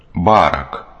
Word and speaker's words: Барак: Барак. С ударением на первый слог.